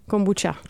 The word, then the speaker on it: kombucha: Vyslovuješ kombucha s ch nebo s č? – Právě jsem se zjišťovala a jakože jde oboje, ne? – No, tak asi jde oboje, jak to říkáš ty? – Kombuča.